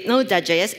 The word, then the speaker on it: Node.js: Uh, on a teeny tiny side note, uh, .js part of Node, Node.js is silent.